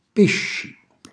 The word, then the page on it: pisci: calabrese